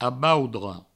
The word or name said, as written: aboudre